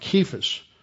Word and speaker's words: Cephas: Or the Greeks didn't pronounce the C like an S. They pronounced it like a hard guttural. "You shall be called Cephas," which is translated a stone.